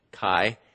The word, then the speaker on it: kai: And basically put, there's a Greek word that it's translated and it's spelled K-A-I, if you were to transliterate the Greek letters into English letters, chi, and it's translated and.